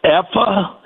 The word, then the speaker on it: Effa: Her name is, I think it's pronounced Effa. It's E-F-F-A, Effa Manley,